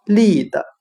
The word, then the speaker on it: lead: 今天我们学习的单词 lead，引导、领导、带领，就可以通过它的发音联想到汉语的立德，立住自己的品德。